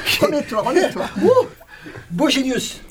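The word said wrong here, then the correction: boygenius: Boy Genius. – Boy, yes. – boy, boy, Genius. Voilà, donc il faut bien le prononcer, excusez-moi.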